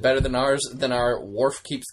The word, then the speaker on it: Worf: Well, Red Dwarf rhymes with Worf. So we established that. It is literally the only thing to rhyme with Worf.